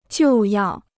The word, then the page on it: 就要: jiù yào